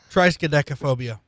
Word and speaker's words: triskaidekaphobia: Spell trichodescophobia. – What? – Sorry, I mispronounced it. Triskaidekaphobia.